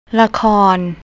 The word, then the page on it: lakorn: thai